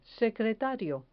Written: secretario

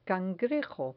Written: cangrejo

